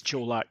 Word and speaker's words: Colac: The accent on the C on Colac, it's a CH? Is that how you're pronouncing that properly? Because I'm hearing Colac and Colac, and I don't know which way to go. – No. It's Colac, yeah.